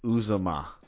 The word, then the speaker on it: Uzomah: in free agency where they signed, let's get his name right, C.J. Uzomah. Uzomah? That sounded right.